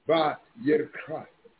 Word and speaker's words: Vayikra: Some of you call it that Romanized version name, Leviticus. It's actually Vayikra. It's spelled Vayikra.